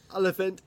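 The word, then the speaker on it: Olyphant: Oliphant.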